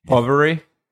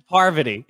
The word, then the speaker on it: Parvati: Poverty. Parvati.